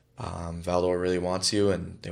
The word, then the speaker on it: Val-d'Or: Val-d'Or. – Val-d'Or, yeah.